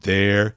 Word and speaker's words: their: Então você fala they mais are contraiu, fica there. They're there. Eles estão ali. They're there. They're there. E também o adjetivo possessivo, né? Their car, their business, é a mesma coisa, mesmíssima pronúncia.